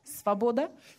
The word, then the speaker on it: свобода: Свобода.